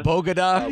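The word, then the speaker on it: Bogotá: It's unbelievable that Bodega, she called it, Bogota, yeah.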